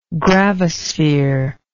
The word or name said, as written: gravisphere